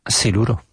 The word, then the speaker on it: siluro: Siluro. – How do you spell it? – S-I-L-U-R-O.